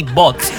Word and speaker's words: Botz: Se escribe así, Botz, B-O-T-X-Z, perdón, B-O-T-Z, Botz, que es Bésame Otra Vez, básicamente resumida, pues.